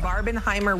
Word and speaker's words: Barbenheimer: Barbenheimer weekend. Am I saying it right? – Well, yeah. – Is that how it is? – Barbenheimer is what they've been calling it.